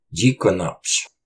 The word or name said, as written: Ciqunapsh!